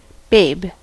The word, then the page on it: babe: American English; /ˈbeɪb/